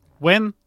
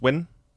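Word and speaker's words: Nguyen: Wen. Jeg fandt ud af det. Øh, Jenny Wen. Skal det udtales Wen? – Ja. N-G-U-Y-E-N e udtales yeah.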